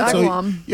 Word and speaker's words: Agawam: Is that how you say it? – Agawam.